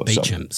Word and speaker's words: Beauchamp: There's a road in my suburb which is called Beecham Road, but of course it's spelt Beauchamp. – Yeah, right. – But it's pronounced Beecham.